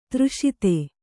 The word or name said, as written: ದುರಾಸದ